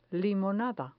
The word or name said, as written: limonada